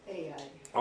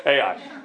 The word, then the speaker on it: Ai: They went from Jericho to a place called Ai, or I, or however you say it. – Ai. – Okay, thank you. Ai.